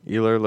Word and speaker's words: Ilir: Obviously, when somebody's transferring to a new weight class, and it's how do you pronounce his first name? – Ilir Latifi.